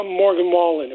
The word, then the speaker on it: Morgan Wallen: Why isn't Morgan Wallen? Am I pronouncing his name correctly? – Mm-hmm. Yes.